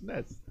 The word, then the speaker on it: NES: It's actually pronounced NES.